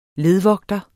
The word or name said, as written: ledvogter